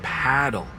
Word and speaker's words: Padel: Wait, is that Padel? – No, that's paddle. Rhymes with saddle.